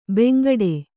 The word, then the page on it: ಬಾಂಗಡಿ: Kannada